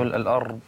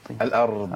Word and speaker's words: الأرض: أولا يعني بسم الله، في لفظ أفلم يسيروا في الأرض، يعني كثير بيقع في تخرير الراء في الأرض. – الأرض. – أيوة نعم. وده خطأ.